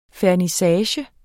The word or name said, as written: fernissage